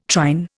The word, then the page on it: trine: Indian